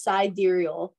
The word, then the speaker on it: sidereal: Sidereal. – It's very confusing. It's sidereal. It's spelled side. Okay. It's spelled like side real, but you pronounce it sidereal.